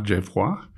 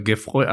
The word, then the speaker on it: Geffroy: Normalerweise müsste man ihn aussprechen Edgar Geffroy. Und ich sag mal, ich bin ja in Duisburg zur Schule gegangen. Wenn ich im Alter von 10 Jahren gesagt hätte, mein Name ist Edgar Geffroy, hätte ich mehr Probleme gehabt. Also habe ich mir diese harte deutsche Aussprache Geffroy angewöhnt.